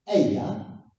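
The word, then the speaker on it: Ehyeh: Because the first one is Eya. Okay? And that's E-H-Y-E-H.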